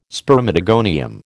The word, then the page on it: spermatogonium: American English